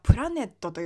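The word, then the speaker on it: planet: プラネットという単語に挑戦されていたので発音していますね。プラーのところがアメリカン A です。ラの子音は L です。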